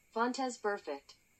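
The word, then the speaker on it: Vontaze Burfict: Vontez perfect.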